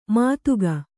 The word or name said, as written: ಮಧುಕ